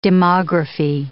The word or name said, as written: demography